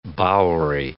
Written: bowery

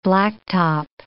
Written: blacktop

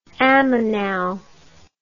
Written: ammoinal

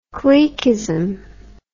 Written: cliquism

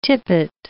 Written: tippet

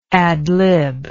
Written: ad lib

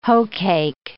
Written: hoecake

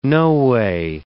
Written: no way

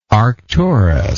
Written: arcturian

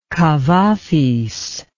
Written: kavafis

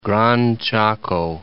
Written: Gran Chaco

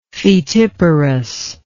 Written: foetiparous

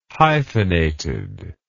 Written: hyphenated

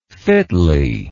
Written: fitly

